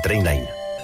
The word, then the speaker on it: Trainline: S'escriu millor preu garantit. Es pronuncia Trainline.